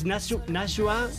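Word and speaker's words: Najwa: No, no, ya, tío, que no sé cómo se pronuncia. Es Nashua.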